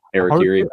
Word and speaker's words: Harakiri: DCAU and then that, and then obviously Harakiri or how is it pronounced? – Harakiri.